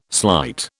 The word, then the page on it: slight: British English